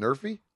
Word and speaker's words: NRFI: So how do you pronounce it? Everybody's been talking. It's called Nerfy.